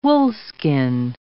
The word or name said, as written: woolskin